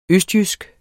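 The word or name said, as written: ØSTJYSK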